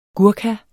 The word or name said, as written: GURKHA